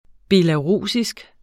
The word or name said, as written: belarusisk